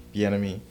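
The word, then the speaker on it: Bieniemy: Bienemy.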